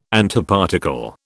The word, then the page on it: Antiparticle: British English